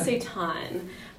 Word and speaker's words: seitan: It's pronounced seitan.